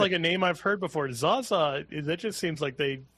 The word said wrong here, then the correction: Zsa Zsa: It doesn't even seem like you're trying to pronounce her name right. It's Zsa Zsa. You said Zaza. Well, I don't know what they, this is a major. – It's Z-S-A. – Yeah, this is a major cultural figure, and you don't even try to say her name correctly. Zsa Zsa I just looked, and apparently her original name was Sari, S-A-R-I, which I'm sure I'm also mispronouncing.